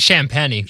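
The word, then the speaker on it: Champagnie: How are we pronouncing this guy's name? Is it Champagne or Champagne? Yeah, Champagne. Yeah, I've heard it pronounced like 17 different ways. Well, it's 24. – I'm pretty sure it's Champagne.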